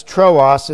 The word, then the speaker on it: Troas: Not Troas. Troas is the pronunciation.